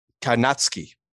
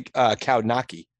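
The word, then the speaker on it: Kownacki: Karnacki. – Okay, you sure? – Yeah, people say Kaunaki, but it's, yeah, Adam, the kid from Greenpoint, heavyweight? – Yeah.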